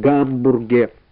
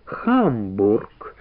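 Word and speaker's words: Hamburg: Кстати, знаете, никак не пойму, почему это у нас твердо так произносят «Гамбург»? Неправильно это. Тамошние жители называют свой город Хамбург.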